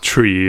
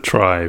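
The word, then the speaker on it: trie: And in retrieval, it's still pronounced tree, and therefore like it should be pronounced tree in my opinion. But for the sake of actually making this somewhat clear, I will pronounce it try for this podcast.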